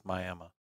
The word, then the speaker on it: Miami: And met with Chief Ben Barnes, which was a really Can you say that right, please? Miami. – I thought about that. Miami, Oklahoma. Mm-hmm.